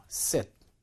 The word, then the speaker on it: sit: 자, 앞쪽에 저 sit는 짧게 발음해요. sit. 뒤쪽에 seat. 이것은 장음입니다. 그래서 길게 발음해서 seat.